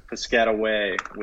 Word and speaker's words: Piscataway: Is that how you say it? With the W. – Piscataway with the W.